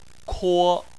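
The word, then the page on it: kuo: tone 1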